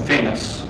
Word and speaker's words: Famous: I'm Famous Lusty. I'm Mr. Arles' house manager at the Sober Living. – What'd you say your name was again? – Famous. – Famous? – Like a famous person.